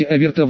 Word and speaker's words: Аверта: Теперь, используя те же самые движения, сотворите заклинание снова, только скажите Аверта вместо противо». Ударение на втором слоге, «Р» немного стрелью.